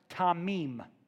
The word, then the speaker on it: tamim: The Hebrew word is pronounced tamim. Tamim.